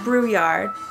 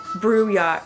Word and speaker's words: Brouillard: Yeah, so Brooke Brouillard. Brouillard. So it's a French word. It actually means fog.